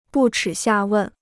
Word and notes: 不耻下问: bù chǐ xià wèn

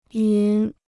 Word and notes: 云: yún

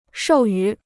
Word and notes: 授予: shòu yǔ

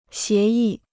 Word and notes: 协议: xié yì

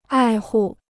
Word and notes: 爱护: ài hù

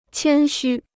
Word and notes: 谦虚: qiān xū

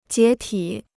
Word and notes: 解体: jiě tǐ

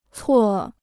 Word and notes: 错: cuò

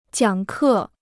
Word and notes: 讲课: jiǎng kè

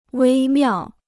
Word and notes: 微妙: wēi miào

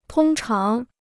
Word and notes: 通常: tōng cháng

